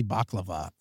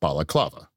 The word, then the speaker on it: Balaclava: Bother to say baklava. Come on. Also baklava. – It's pronounced balaclava.